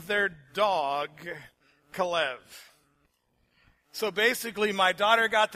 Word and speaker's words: Caleb: In Hebrew, it's pronounced Kalev.